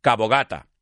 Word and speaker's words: Cabo de Gata: O como lo decimos allí, Enrique, vamos a ser honestos, Cabo Gata. No se dice Cabo de Gata. De, por favor, Cabo Gata. That's how we say it in my family.